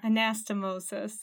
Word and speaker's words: anastomosis: Is that how you say it? – Anastomosis.